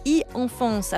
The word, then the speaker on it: e-Enfance: ça s'appelle e-Enfance. Alors je dis i parce que c'est à l'anglaise, mais ça s'écrit e-Enfance.